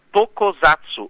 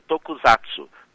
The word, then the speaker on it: Tokusatsu: é Tokusatsu, ou Tokusatsu, tá? Eu tinha pronunciado errado.